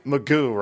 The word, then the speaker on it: McGough: It's Mike McGough, right? – Yeah.